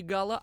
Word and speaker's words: Гала: не, не Гала, а, а Галя там, Галка, Гала. Ну, можно, конечно, и так, но все-таки на французский манер с ударением на последний слог уместнее было бы произносить «гала».